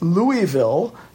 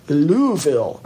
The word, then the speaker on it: Louisville: Now, that's how most of us would say it, but I think the locals say Louisville. And even I'm not doing it quite right. They have their own little way of doing it, Louisville, something like that.